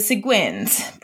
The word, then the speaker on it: Seguin: The Seguins. Pronunciation? Yes, I think that's correct. Seguin, like penguin.